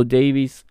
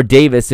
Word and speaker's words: Davies: It's Alphonso Davies, or Davis if you prefer the British pronunciation, like we heard on CBS All Access this week.